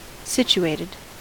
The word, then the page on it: situated: American English